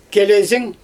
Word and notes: kelezing: Xyzyl

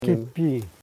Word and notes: kipi: ki.pi

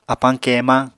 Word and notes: apañ keemañ: Apatani

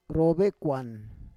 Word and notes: rovekuan: Roon

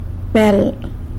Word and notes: bel: sanlucasquiavini

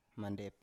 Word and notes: mandep: Biak

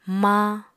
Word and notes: maañ: apatani

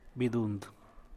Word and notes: vaag: San Mateo Macuilxochitl Zapotec

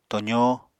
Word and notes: tozó: Apatani

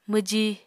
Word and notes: mwji: Apatani

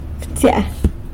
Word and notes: btsya: sanlucasquiavini

